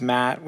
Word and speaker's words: Matt: Yeah, it's I was the fat kid, and it doesn't help that my name is Matt, which obviously rhymes with fat because kids can be quite Ouch.